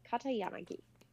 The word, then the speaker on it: Katayanagi: Katayanagi.